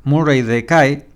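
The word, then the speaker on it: Murray the K: Entonces, la discográfica lo persiguió a Murray Decay. ¿Se pronuncia así? Murray, Murray Decay. Murray Decay es porque es Murray el K.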